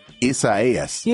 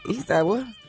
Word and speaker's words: Isaias: It's spelled the same way, but this hurricane is called Isaias.